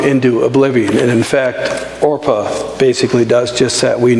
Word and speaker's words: Orpah: They marry Moabite women, Orpah and Ruth. And people often pronounce them as Oprah and Ruth.